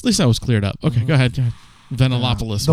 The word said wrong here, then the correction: Yiannopoulos: Milo Yiannopoulos.